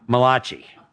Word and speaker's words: Malachi: All right, that's the way you pronounce it, Malachi.